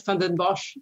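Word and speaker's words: Vanden Bossche: I'm afraid that anybody who gives van den Bosch, that's how you pronounce his name, Gert van den Bosch, my mom's Dutch, so I know how to pronounce it, give him any credence is misled.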